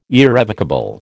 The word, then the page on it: irrevocable: American English